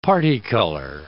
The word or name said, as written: parti-color